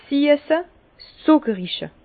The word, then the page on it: 's siesse, 's zùckerige: Bas-Rhin; Herrlisheim